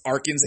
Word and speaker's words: Arkins: And Audrey's last name is pronounced Arkins, A R K I N S.